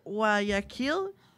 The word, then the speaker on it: Guayaquil: Soy de Guayaquil. Creo que se pronuncia así.